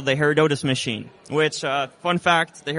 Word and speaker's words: Herodotus: Herodotus.